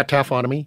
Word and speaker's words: taphonomy: So taphonomy. Am I saying it right? – Yeah, taphonomy.